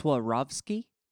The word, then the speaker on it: Swarovski: I think it's Swarovski. – What did I say? – Swarovski? – Yeah, well, you know, close enough. – Swarovski. Swarovski. – Is the R before the V? – Yeah, yeah, yeah.